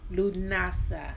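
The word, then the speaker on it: Lughnasadh: And the actual name is actually pronounced different than how it's spelled. So Lunasa is actually the pronunciation of the name.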